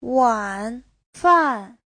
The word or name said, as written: wan3fan4